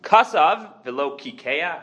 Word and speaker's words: kasav velo kikea: If you write a ksoves kaka, which again is a tattoo, kasav velo kikea, according to the Mefarshim, that's the right way to pronounce it, but there's 100 different ways that people pronounce that word.